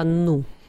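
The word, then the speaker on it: New: Он мне объяснял, что Нью-Йорк, но если по-английски произносить, то правильно будет не Нью, а Ну.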